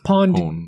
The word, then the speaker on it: PWN: Yeah, it's Pawn CNC. – Do you say it like that? – Or I was spelling it out. – PWN. – Yeah, it's Pawn, I think.